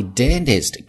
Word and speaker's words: dentist: But my dentist keeps saying 停，这是一个异群，但是我的牙医呢一直说 my 双元音 my I。 Dentist, dentist, dentist, 美式发音中间的 t 发为含糊的 d, dentist.